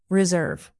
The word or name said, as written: reserve